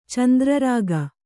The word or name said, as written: ಚಂದ್ರರಾಗ